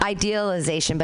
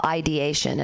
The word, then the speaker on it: ideation: It's not idealization, but it's suicidal ideation.